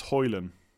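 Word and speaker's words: Højlund: It's Hoyland. You don't pronounce the D at the end. It's not Hoyland. It's Hoyland. I have to try and get that right.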